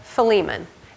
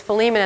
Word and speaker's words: Philemon: And I was so disappointed that it was Philemon and not Philemon, because I thought Philemon is a wonderful southern pronunciation. But it's not correct, unfortunately.